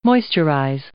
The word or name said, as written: moisturize